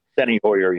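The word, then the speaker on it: Steny Hoyer: And the guy who's Steiny, I guess, I don't know if that's how you say it. – Steiny Hoyer, yeah.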